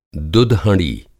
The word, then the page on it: दुध-हँड़ी: Hindi